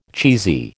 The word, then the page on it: cheesy: American English